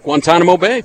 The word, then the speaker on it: Guantanamo: Give a big shout out to our last guest, Staff Sergeant Blake Walker's parents, who are listening in Guantanamo Bay. Did I say that right? – Guantanamo Bay.